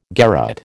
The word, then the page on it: garotte: American English